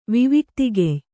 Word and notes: ವಿವಿಕ್ತಿಗೆಯ್: Kannada

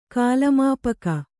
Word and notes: ಕಾಲಮಾಪಕ: Kannada

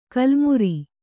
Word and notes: ಕಲ್ಮುರಿ: kalmuri

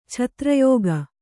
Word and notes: ಛತ್ರಯೋಗ: chatra yōga

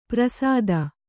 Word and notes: ಪ್ರಸಾದ: prasāda

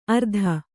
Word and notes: ಅರ್ಧ: Kannada

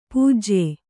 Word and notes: ಪೂಜ್ಯ: pūjya

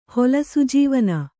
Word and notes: ಹೊಲಸುಜೀವನ: Kannada

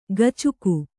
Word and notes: ಗಚುಕು: gacuku

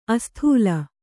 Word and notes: ಅಸ್ಥೂಲ: asthūla

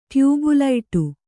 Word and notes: ಟ್ಯೂಬುಲೈಟು: tyūbu laiṭu

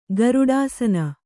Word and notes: ಗರುಡಾಸನ: Kannada

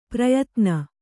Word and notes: ಪ್ರಯತ್ನ: prayatna

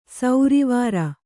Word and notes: ಸೌರಿವಾರ: sauri vāra

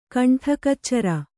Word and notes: ಗಂಟೆಕಚೋರ: gaṇṭe kacōra

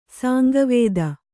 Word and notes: ಸಾಂಗವೇದ: kannada

